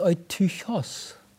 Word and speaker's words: Eutychos: Und zwar einen Namen, der heißt Eutychos oder Eutychus ausgesprochen.